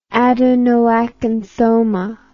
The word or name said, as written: adenoacanthoma